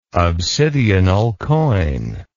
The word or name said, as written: obsidional coin